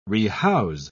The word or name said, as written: rehouse